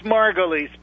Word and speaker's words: Margolis: It's Margolis, but, you know, everybody has a problem with it, including myself, so don't feel badly. But I prefer Margolis. That's Margolis. – What my father used to use.